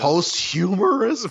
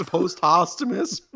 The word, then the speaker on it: posthumous: Posthumorous, posthumous.